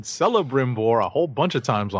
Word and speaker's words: Celebrimbor: And it's pronounced Celebrimbor.